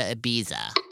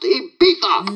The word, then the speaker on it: Ibiza: Ibiza. – It's pronounced Ibiza.